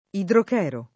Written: idrochero

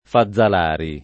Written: Fazzalari

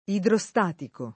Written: idrostatico

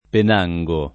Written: Penango

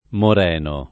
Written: Moreno

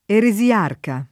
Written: eresiarca

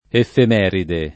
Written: effemeride

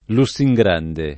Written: Lussingrande

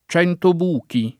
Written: Centobuchi

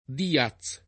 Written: Díaz

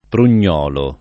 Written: prugnolo

